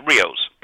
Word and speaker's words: Rios: Yes, Rios. Perfect. R-I-O-S. Perfectly right. – Okay. I'm saying the soft S, and you're saying it like a Z, but...